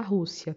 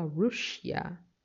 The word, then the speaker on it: Russia: Não se fala Rússia, tá? Se escreve Rússia, mas em inglês se fala Russia.